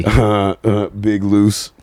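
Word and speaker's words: Big Luse: Big Loose. – Big Loose? – Yeah, L-U-S-E.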